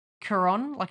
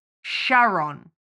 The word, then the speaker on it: Charon: From my knowledge, it's pronounced Charon, it's C-H, but it's K. That's how you pronounce it. And the only thing that shit me in the entire film is that Winston says Charon, like Sharon.